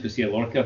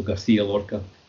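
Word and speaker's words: García Lorca: Hi, Garcia Lorca's fine, English Garcia Lorca. I presume in Spain it's more good, they do have that sound, so Garcia Lorca.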